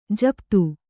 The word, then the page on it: ಜಪಿತ: japita